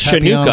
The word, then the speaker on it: Chanukah: I believe that's pronounced Chanukah. I don't want to correct the Jewish member of our team, but I believe that's pronounced Chanukah, isn't it? – I believe.